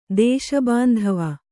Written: ದೇಶಬಾಂಧವ

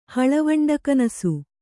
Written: ಹಳವಂಡಗನಸು

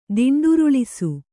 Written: ದಿಂಡುರುಳಿಸು

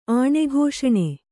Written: ಆಣೆಘೋಷಣೆ

